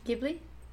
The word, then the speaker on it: Ghibli: Ghibli? You and your giblets. – I pronounce it how the Japanese pronounce it.